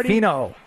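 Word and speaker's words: Finau: Fino. – Tony Fino.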